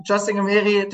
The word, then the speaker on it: Justin Gemeri: Justin Gemeri, der Name kommt aus dem Serbischen, wurde ein bisschen eingedeutscht von der Schreibweise her, aber Okay. – ist so komplett richtig ausgesprochen worden.